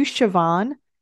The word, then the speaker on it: Siobhan: And I called out, I said, "Are you Siobhan?" And she said, "Yes. And thank you for pronouncing it right."